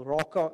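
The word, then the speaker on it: Raca: Raka, if you pronounce it correctly, which I can't do in Aramaic, it's a sound that is very similar to clearing your throat, if I can give a euphemism.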